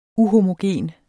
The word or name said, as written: UHOMOGENT